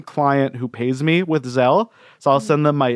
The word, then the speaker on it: Zelle: Yeah, it's spelled Z-E-L-L-E, which the first time I read it, I thought it was Zelly. But no, it's Zelle.